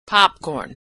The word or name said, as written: popcorn